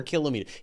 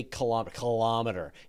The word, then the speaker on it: kilometer: But the one that I will admit that we say it wrong is kilometer, that we should say kilometer. It's kilogram, kiloliter. Exactly. It should be kilometer. And yet we say kilometer.